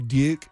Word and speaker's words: Duke: It's a D-E-W-K. – It's like there's a southernness to it, I think. Duke. – Or like D-Y-U-K-E. – Yeah, there's a Y in there. Duke. I've got friends' parents who have that same pronunciation of the long U that way.